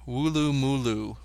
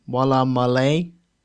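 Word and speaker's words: Woolloomooloo: All I had was A-L-L-A-L-L-A. – So, Walla mala, or Walla malay, it might be, or Wulu mulu, with double O's everywhere. – I'm gonna pronounce it Walla... No, Walla malay. – No, it's Wulumulu.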